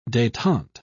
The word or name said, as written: detente